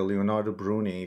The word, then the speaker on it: Leonardo Bruni: And also you mentioned an important character, Leonardo Bruni, if I'm pronouncing the name correctly.